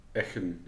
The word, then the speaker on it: Eggenberg: Volgens mij is het Eckenberg, zeg ik dat goed? Echen, ja. Ik, ja.